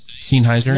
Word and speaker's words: Sennheiser: Is that how you say it? – Sennheiser.